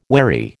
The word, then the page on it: wherry: American English